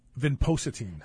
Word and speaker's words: vinpocetine: Vinpocetine. – Vinpocetine is a derivative of the periwinkle plant.